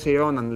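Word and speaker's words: Ronan: Και λένε ότι Ρόναν, Ρονάν, πώς, πώς προφέρεται, λένε ναι, ότι έχει... Ρόναν, Ρόναν λέγεται.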